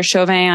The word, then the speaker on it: Chauvin: So you can take a screenshot of this, tag me at Heather Chauvin on Instagram. My last name is spelled C-H-A-U-V-I-N, and tag Erin as well on Instagram at ELTHORP.